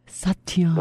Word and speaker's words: Satyen: Okay, it's pronounced Satyen. – Satyen. – Okay, Satyen, and it's spelled S-A-T-Y-E-N. And my last name is Raja, R-A-J-A.